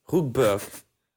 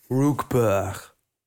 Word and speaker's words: Rookburg: Kan Rookburg uitspreken, maar ik krijg, die R er tussen niet uitgesproken. Rookburg. ja. – Ja, dat komt omdat je in Limburg woont. – Rookburg.